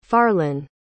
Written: farlan

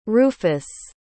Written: ruffus

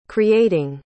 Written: creating

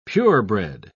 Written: purebred

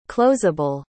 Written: closable

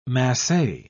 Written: masse

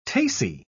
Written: tace

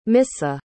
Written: missa

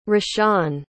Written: rashaan

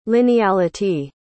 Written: lineality

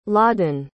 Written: laadan

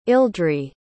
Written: ildri

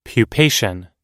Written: pupation

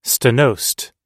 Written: stenosed

